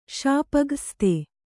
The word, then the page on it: ಶಾಪಗ್ರsಸ್ತೆ: śapa graste